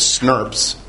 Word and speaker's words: snRNP: which are often pronounced as SNRPs.